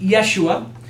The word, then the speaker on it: Yeshua: In Hebrew, it is pronounced Yeshua.